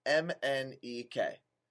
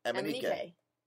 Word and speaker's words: MNEK: And you pronounce it M-N-E-K. Just like it's spelt with the letters M-N-E-K. – But when you say it fast, it's M-N-E-K.